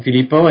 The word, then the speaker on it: Filippo: I am Filippo, and my name is spelled F-I-L-I-P-P-O.